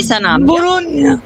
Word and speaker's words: Sanabria: E poi Torino Como vici il Torino, segna Sarabia. – Ok, Sanabria.